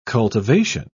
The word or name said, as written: cultivation